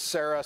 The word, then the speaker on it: Sarai: His wife's name is typically spelled Sarai, but we pronounce it as Sarah.